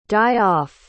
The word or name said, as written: die-off